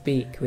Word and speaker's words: beak: Yo siempre he pensado que era peak, hasta que hemos hecho este vídeo. – It's beak with a B.